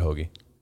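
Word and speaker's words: Hoge: They pronounce it Hoagie.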